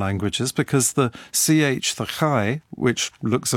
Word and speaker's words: chi: But there is another Greek letter, the chi, which is something like the ch in lock.